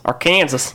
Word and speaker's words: Arkansas: But was it S-A-W, like saw? – Yeah, S-A-W. Yeah. It's pronounced Arkansas.